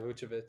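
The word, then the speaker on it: Vučević: That's how you say it, right? – Yeah, Vucevic.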